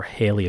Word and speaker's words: Halley: Yet, at least for today's podcast, perhaps the best place to begin is with Sir Edmund Halley, or Halley as he's pronounced in the United States.